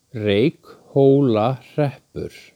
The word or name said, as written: Reykhólahreppur